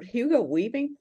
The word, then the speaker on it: Hugo Weaving: Yeah, and I'm not a Marvel per Hugo Weaving.